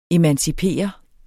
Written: EMANCIPERE

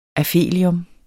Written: aphelium

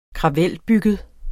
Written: kravelbygget

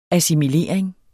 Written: assimilering

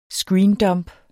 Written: screendump

